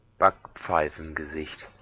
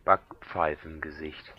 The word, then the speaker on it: Backpfeifengesicht: Backpfeifengesicht. – You want to hear that again? – If you can hear that without laughing. – Backpfeifengesicht. – Backpfeifengesicht.